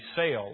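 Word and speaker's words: sale (צל): It occurs at least 49 times in our Old Testament, and if we were to pronounce the Hebrew word, it would be sale, like you're going to a sale at a store.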